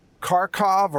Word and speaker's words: Kharkiv: And they're not far now, just a couple of kilometers away from the outer edges of the second largest city in Ukraine, known as Kharkov or Kharkiv. It's spelled and pronounced two different ways, depending on whether you're more Russian or more Western.